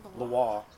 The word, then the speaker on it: Loire: How do you pronounce it? Is it Loire or Loire? – Loire, region in France.